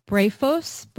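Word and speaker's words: Brephos (βρέφος): And in Luke, you know, John the Baptist leaps in Elizabeth's womb, and Luke refers to him as Beatros, or can you pronounce it for me? Brephos.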